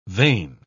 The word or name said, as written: vane